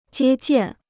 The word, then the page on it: 接见: jiē jiàn